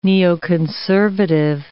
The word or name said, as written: neoconservative